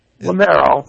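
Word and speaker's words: Lameiro: I'm sure I'm going to butcher your last name, Lemerio. – Lemero.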